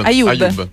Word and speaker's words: Ayub: Ayub, perdonami. – Sì, come YouTube, più o meno.